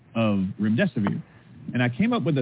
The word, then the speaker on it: Remdesivir: Because when they would try to repeat it back to me, the hosts, they couldn't say Remdesivir. So I told them, just remember, it rhymes with Run, Death is Near.